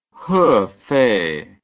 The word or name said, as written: hefei